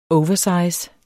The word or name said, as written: oversize